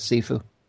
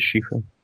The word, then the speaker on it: Shifu: Because I was always thought it was Sifu. – No, it is Shifu.